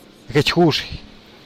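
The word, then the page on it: gechgux: Teotitlán del Valle Zapotec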